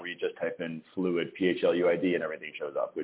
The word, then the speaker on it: Phluid: And it's spelled P-H-L-U-I-D. – Yeah, P-H-P-H is the balance.